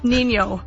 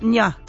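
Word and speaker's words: Niño: So the little accent on top of the N makes it right. Nya.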